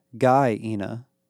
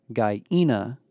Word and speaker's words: Gaina: You've got to stop saying it like that, Barrett told her. – Like what? – Gaina. Isn't that how you say it? – It's Gaina, with the accent on the second syllable.